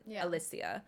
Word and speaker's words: Alicia: And it's a male reading, like, the rest of it for, you know, Theo and then Alicia. That's how they say her name, by the way. – Oh, nice. – And I did not know that. I was it's very British.